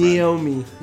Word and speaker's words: NAOMI: Think Shenmue, think Ryo's girlfriend. – Naomi.